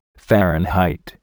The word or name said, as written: fahrenheit